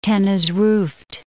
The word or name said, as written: Tanezrouft